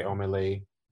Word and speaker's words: Omahlay: O-M-A-H-L-A-Y. – Okay, Omalay. – He's Nigerian. – Yeah, so it's Omar and Lay separate.